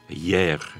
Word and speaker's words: Hyères: Dat is namelijk hoe spreek je het in godsnaam uit? Want het wordt H, Y, E accent aigu, R, E, S geschreven. Jair. Het lijkt wel alsof je gisteren zegt Jair.